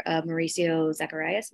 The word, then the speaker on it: Mauricio Zacharias: I'm curious how you and your co-writer, Mauricio Zacharias, am I saying that right? – Yeah.